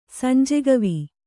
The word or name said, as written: ಸಂಜೆಗವಿ